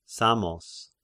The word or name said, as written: Samos